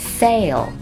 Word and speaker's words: sail: Sail, S-A-I-L, sail, 是指的帆船的船帆。要注意结尾的字母 L 的发音哦。 Sail, 舌尖在上齿的背后顶得很好。